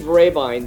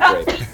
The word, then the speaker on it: Raybine: The old German spelling was Rehbein, and I changed it to Raybine because a lot of people couldn't pronounce the name in its current, you know, in its original German form.